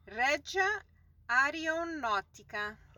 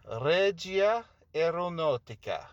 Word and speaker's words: Regia Aeronautica: So how do I say this term here? – Regia Aeronautica. – Regia Aeronautica. – Bravo.